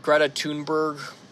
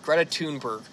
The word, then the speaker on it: Greta Thunberg: So, a couple days before getting arrested by the Romanian police, he starts some drama with that one Swedish girl her name is pronounced Greta Thunberg. People have been pronouncing it wrong. I'm going to be the man who's good in this situation. I'll say your name correctly Greta Thunberg.